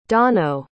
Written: donau

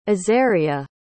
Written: azaryah

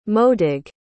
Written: modig

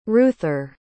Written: reuther

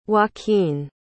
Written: joaquin

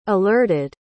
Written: alerted

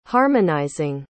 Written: harmonizing

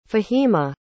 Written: Fahima

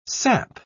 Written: sap

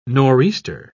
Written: nor'easter

